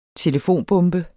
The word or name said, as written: telefonbombe